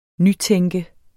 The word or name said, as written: nytænke